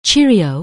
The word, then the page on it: cheerio: anglais